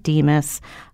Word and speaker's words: DMHAS: Yes, if you just put in your browser, Connecticut DEMIS, you will be taken right to it. – And DEMIS is spelled DMHAS for Keith.